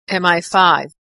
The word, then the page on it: MI5: American English